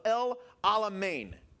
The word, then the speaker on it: El Alamein: It's called the Battle of El Alamein.